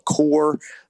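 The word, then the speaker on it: Kore: They came out with a new flagship called the Core. I think I'm pronouncing that right, Core with a K, K-O-R-E.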